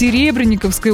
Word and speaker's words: Серебренниковской: Слушайте, а что сейчас происходит с Серебренниковской улицей? Да-да, именно так ударение. Федор Серебренников, большевик, революционер, поэтому именно туда ударение ставим.